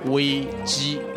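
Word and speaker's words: Wei Ji (危机): The Chinese word for crisis is pronounced as Wei Ji.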